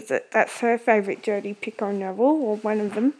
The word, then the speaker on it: Picoult: That's how you pronounce it, Picoult.